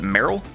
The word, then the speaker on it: Merle: M-E-R-L-E. Is it Meryl? – I think it's Meryl.